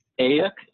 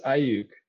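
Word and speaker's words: Aiyuk: And then how about this guy in the Niners you took round 14, Brandon Ayuk? Am I saying that right? What's his story? – I believe it's Ayuk.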